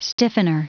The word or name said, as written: stiffener